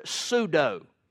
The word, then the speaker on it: pseudo: The word false there would give us our English word pseudo. Pseudo-prophets. Do you know what pseudo means, spelled with a silent P at the beginning?